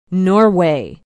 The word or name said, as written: norway